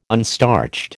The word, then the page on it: unstarched: American English